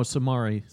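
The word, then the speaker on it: Samori: Moving on, Sammy Toure. I didn't pronounce that correctly. – No, Samari. – Samari Toure. There we go.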